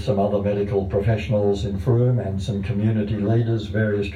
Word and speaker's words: Frome: It's spelled, if you want to look it up on the net, it's spelled F-R-O-M-E, but pronounced Frome.